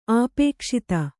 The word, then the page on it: ಅಪಕ್ಷಾತಿ: apakṣāti